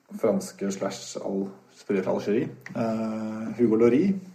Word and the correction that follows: Lloris: Vår eh, Loris. Lloris. Lloris. Spansk etternavn. Spansk etternavn. Ja. Jeg som trodde at eh, man ikke uttalte S på franske spillere.